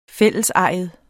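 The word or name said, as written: fællesejet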